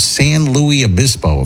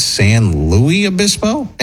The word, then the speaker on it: San Luis Obispo: San Luis Obispo, if I'm saying that correctly.